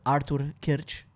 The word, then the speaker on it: Artur Kyrcz: Uh, I'm gonna pronounce it's Artur Kyrcz. That's gonna be a hard one for a lot of people to pronounce.